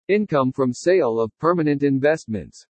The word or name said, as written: INCOME FROM SALE OF PERMANENT INVESTMENTS